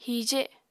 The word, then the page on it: hije: Apatani